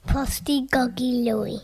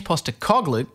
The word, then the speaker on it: Postecoglou: Posty Goggy Louie. Is that actually how you say it? Mr. Pogolo. Ange Postecoglou, he's one of the nicest chaps you'll ever meet.